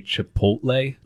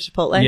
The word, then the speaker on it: Chipotle: I used to say Chipotle instead of Chipotle.